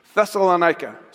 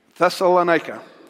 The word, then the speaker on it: Thessalonica: Thessalonica, say it with me. That's hard to say, isn't it? Thessalonica. Context. We could read about the church in Thessalonica in chapter 16 of Acts,